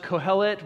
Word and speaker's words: Kohelet: In Ecclesiastes, which in Hebrew is pronounced Kohelet,